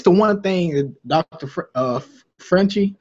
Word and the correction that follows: Fauci: I'm saying his name wrong. – Fauci.